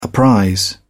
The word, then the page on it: apprise: British English